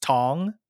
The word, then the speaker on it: Thong: Like we would always tell people it's pronounced Tong, the H is silent. But a lot of people, like when they see it, their first thought is to go Thong just because, you know.